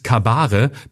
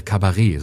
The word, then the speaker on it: Kabarett: ReporterInnen in einem Anflug von Sascha Lobotomie sich für politisches Kabare, bildungsbürgerlich ohne T am Ende, ausgesprochen, aha, ach Kabare, Kabarett soll das hm, sein.